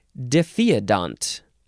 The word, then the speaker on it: diphyodont: It means double or bipartite, as in the example, diphyodont. Diphyodont, yes, that is how you pronounce that, because that is the last word in this episode, and I looked at the pronunciation.